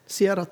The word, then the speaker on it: Ciara: Want we hebben dus de eerste die uh, was storm uh, Ciara. Hoe spreek je het uit? Ciara, Ciara. – Sierra, toch? Ja, Sierra.